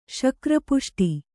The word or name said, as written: ಶಕ್ರಪುಷ್ಟಿ